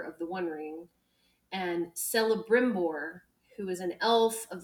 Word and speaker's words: Celebrimbor: And it is Celebrimbor. – Celebrimbor.